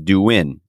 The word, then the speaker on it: Douyin: Which is pronounced Douyin.